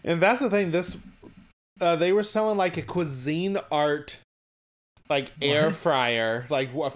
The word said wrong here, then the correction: Cuisinart: Did you say cuisine art? – Is that how you pronounce it? – It's Cuisinart.